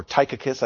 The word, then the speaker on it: Tychicus: Or Tychicus, I think is probably the better way to pronounce it.